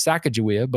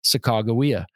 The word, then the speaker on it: Sacagawea: Now, we were taught in school her name was Sacagawea, but it's actually pronounced Sacagawea.